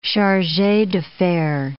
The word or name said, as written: charge d'affaires